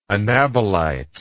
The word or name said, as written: anabolitic